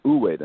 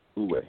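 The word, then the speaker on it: wu wei: And it's pronounced Uwe then. Uwe. – Uwe, yeah.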